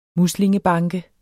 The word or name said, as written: muslingebanke